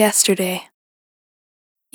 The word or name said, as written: Yesterday